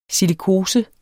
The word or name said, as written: silikose